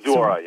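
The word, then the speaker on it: Zuora: Did I say that right? – Zorora, yeah. – Zorora. Z-U-O is the ticker there.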